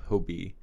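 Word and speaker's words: Hobbii: But there's this website called Hobby, but it's spelled H-O-B-B-I. So it's spelled like hobby.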